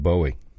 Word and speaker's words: Bowie: So six I thought was interesting as well, that Bowie, when the name Bowie was put together, it was meant to rhyme with Joey, not Howie. So however you say David Bowie, it sounds like Howie, but it was meant to be Joey.